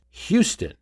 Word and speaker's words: Houston: Time magazine still found it necessary in 1928 to offer its readers a pronunciation guide to say that it was pronounced Houston, as opposed to the street in New York City, Houston.